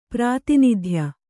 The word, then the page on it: ಪ್ರಾತಿನಿಧ್ಯ: Kannada